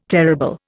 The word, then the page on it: terrible: Indian